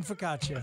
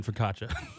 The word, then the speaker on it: focaccia: You don't pronounce the I in focaccia. focaccia, whatever.